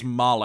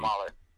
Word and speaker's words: Mayle: The first one that comes to mind that can make an impact right away is Vince Mayo. I don't know if I'm pronouncing his last name right. – I think it's Male. I think Braulio said Male earlier, but either way, everybody knows who we're talking about. Vince M-A-Y-L-E.